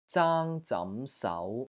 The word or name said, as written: ZAANG1 ZAM2 SAU2